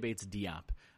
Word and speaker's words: Bates Diop: So uh, going forward, we're going to do our best to call him Keita Bates Diop, since that is what he has uh, made the decision on. He's requested that that be the pronunciation of his last name.